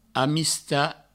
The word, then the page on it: amistat: amista